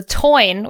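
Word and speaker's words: Táin: And the the the toin, which is an Irish word I do know how to say right, is spelled the Tain, T A I N T A I N.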